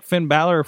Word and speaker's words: Finn Balor: They brought out, like, Finn Balor from... I said it right. Did I say it right? – Yes.